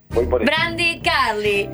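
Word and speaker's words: Brandi Carlile: La uno es Ozzy, la dos es Idles, la tres es Brandy Carly, la cuatro es Beck y la cinco es Brian Adams. – Las cinco estuvieron nominadas. Por Brandy no sé bien la pronunciación. – Brandy Carly.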